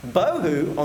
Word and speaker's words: bohu: Bohu, on the other hand, is not a word. It just rhymes with tohu.